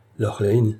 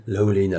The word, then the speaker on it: Lorlaine: Je suis Nathalie Lorlaine. – Elle a prononcé Lorlaine, Lorlaine à l'anglaise.